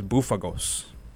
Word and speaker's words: Bouphagos: But the guy's name is Bufagos. B O U P H A G O S. – Bufagos. B O U P H. Pronounced boof.